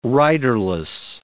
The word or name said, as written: riderless